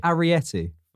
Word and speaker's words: Arrietty: The Cat Returns. Ariety. – Ariety, boy. – Ariety? Variety? – Okay, it's spelled A-R-R-I-E-T-T-Y. – Ariety.